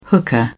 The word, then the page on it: hooker: British English